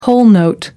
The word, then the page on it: whole note: American English